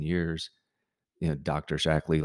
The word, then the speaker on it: Shaklee: So I've you know we we recommend and have taken for decades uh, Shaklee products, and most of the time when you say the word Shaklee, people are well they always pronounce it wrong and call it Shakely, but they always go, mm-hmm.